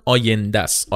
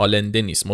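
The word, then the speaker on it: Allende: اسمش هم آینده است آلنده نیست ما تو فارسی اشتباه تلفظش میکردیم میگفتیم آلنده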